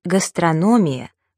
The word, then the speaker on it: гастрономия: Правильно произносить «металлургия», «индустрия», «терапия», но при этом «гастрономия», «кулинария», «логопедия».